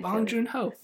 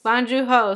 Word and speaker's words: Bong Joon Ho: Bong Joon Ho. – Bong Joon Ho.